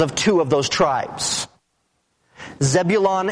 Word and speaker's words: Zebulun: Naphtali and Zebulun, that's the way they're pronounced in the Old Testament.